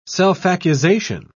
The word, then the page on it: self-accusation: British English